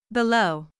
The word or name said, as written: below